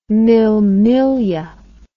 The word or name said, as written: mille miglia